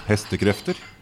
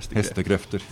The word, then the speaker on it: Hestekrefter: How do you say that in Norwegian? – Hestekrefter. – Hestekrefter, yeah.